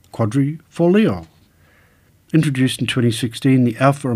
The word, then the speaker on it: Quadrifoglio: Quadrifoglio is how to say Quadrifoglio.